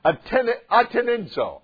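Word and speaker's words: Atenidzo (ἀτενίζω): Atenidzo. Atenidzo. Say it with me. Atenidzo. Once you get your pronunciation, Pastor Kevin, we'll say it with you. Atenidzo. Sounds like attention, doesn't it?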